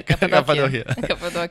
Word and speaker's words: Cappadocia: Cappadocia.